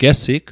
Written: gesig

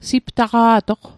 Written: siptaqa'toq

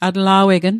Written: atla'wegn

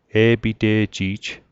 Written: e'pite'ji'j

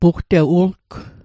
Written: pugtewu'lgw

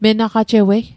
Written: menaqajewei

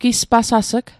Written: gispasas'g

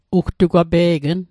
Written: ugtugwape'gn

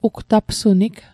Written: ugtapsunig

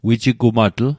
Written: wijigumatl

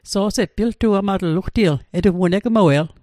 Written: piltuamatl

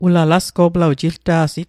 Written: jilta'sit

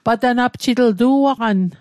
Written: patanapjit

